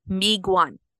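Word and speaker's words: Miigwans: So just real quick to clarify, because I just found an Ojibwe pronunciation. It's Miguan.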